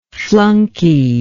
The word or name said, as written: flunkyism